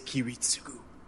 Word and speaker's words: Kiritsugu: Kitsurigu? Kitsurigu? – I don't know. Japanese pronunciation. – Ragu! – Yeah, it is Kiritsugu.